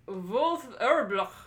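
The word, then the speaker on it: Wolf Erlbruch: By Wolf Erblach. – Wolf Erblach. Exactly. – Yeah. – That's how you pronounce it. – Right? Wolf, it'd be a V, right? – Yeah, that's a V.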